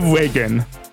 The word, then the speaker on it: Regen: What will Owens think of Regen? – I'm sorry? It's pronounced regen, as in regeneration.